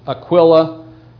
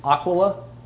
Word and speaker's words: Aquila: Now, you'll hear some people, just as a lot of us will sometimes pronounce it, just we'll say Aquila, but you'll hear others, perhaps more correctly, Aquila.